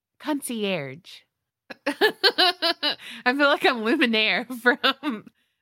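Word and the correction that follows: Lumière: did I say his name weird? – Yes. – Luminaire. How do you say it? – I don't know. Oh. Lumiere? – Yeah, that's it.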